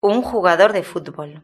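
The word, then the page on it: un jugador de futbol: European Spanish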